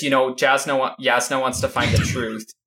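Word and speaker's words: Jasnah: Yasna. – This is a preview for the pronunciation podcast where Matt continually says Jasna.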